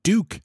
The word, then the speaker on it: Duke: Duke, not pronounced duck, but Duke, as in Duke d'Anville, the French captain guy.